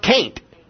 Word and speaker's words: can't: But if you go into some places, the word C-A-N apostrophe T is pronounced can't. There is no I in that word. W-A-S-H-I-N-G-T-O-N is what?